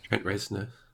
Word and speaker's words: Reznor: Trent Reznor.